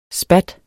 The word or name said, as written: spat